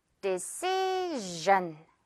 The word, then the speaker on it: decision: 역시 S-I-O-N. 그러면 decision, gen. 어때요, 여러분? John이라는 이 한글 기억하면 그렇게 어렵지 않죠?